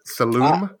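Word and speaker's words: Saloum: My number 8 is Saloom. Is that how you pronounce it? Saloom? – Sure.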